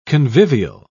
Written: convivial

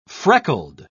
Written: freckled

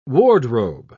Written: wardrobe

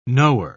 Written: knower

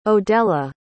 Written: odella

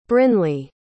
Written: brinley

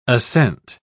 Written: ascent

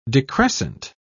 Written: decrescent

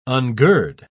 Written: ungird

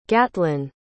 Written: gatlin

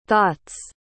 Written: thoughts

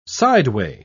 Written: sideway

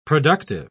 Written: productive

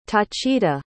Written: tacita

